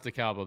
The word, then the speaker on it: DeKalb: No, it's pronounced DeKalb up there, and I promise you that.